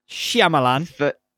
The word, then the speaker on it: Shyamalan: Yeah, you're absolutely nailing that. – What? – The pronunciation. – Shyamalan.